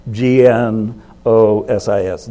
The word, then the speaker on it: gnosis: G N O S I S, gnosis. gnosis. G uh, is silent.